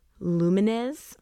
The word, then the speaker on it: Lumines: With a team containing many of the minds behind rhythm games like Rez and Lumines. Yeah, I said it right.